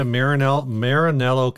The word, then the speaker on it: Maranello: Red Sparkle Italia Maranello Classic.